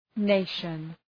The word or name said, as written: nation